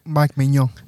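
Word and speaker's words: Mike Maignan: Mike Mignon.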